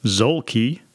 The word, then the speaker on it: Zuelke: Over the weekend, I had a conversation with Mr. Layne Zuelke. That's how it's pronounced. Zuelke.